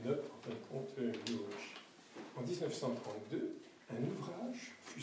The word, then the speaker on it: Miłosz: Comme c'est un El Barré, ça se prononce Miłosz. Comme ça détaille. Plutôt que de dire Miłosz, dites au moins Miłosz. Parce que SZ se prononce che.